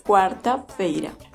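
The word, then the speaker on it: quarta-feira: Y la pronunciación en portugués para este día es cuarta feira.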